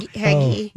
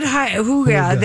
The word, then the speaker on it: hygge: Not Heggy. – No, it's not hygge. That's Danish. Hygge. – How did you pronounce it? – Hygge. – Oh, I always call it Heggy. – Yeah, I know. – That's a frozen pizza. – It's hygge. Yeah. Heggy's is the pizza.